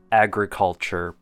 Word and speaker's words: agriculture: Next up, agriculture. Four syllables. First is the letter A. When pronounced, it's A. Gr, short U sound. Cull, think of the word cull, C-U-L-L. And chur, C-H sound with an E-R ending. Agriculture. And our last one for pronunciation would be three linking words.